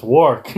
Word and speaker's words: Warwick: It, it's Warwick. – yeah. And again, you just in this initial change, like because like we see Warwick